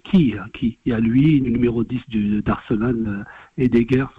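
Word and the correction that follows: Ødegaard: c'est ça, si j'ai pas écorché son nom. – Martin Odegaard, ouais.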